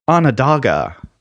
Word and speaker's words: Onondaga: Episode 121 on Mark's question about the Iroquois, Onondaga is pronounced Onondaga. Onondaga, the correct pronunciation.